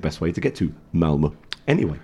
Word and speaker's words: Malmö: Katie works for a company that has its headquarters in Sweden, just outside Malmö. And their parent company, their investors... – Someone's worked out how to pronounce it. – There were a couple of raised eyebrows coming this side of the table. Malmö.